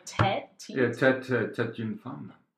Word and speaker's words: tête d'une femme: How do you say that? Like tête d'une femme. – Okay, what he said.